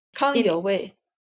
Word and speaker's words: Kang Youwei: Kang Youwei.